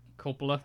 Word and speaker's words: Coppola: He's the nephew of Francis Ford Coppola.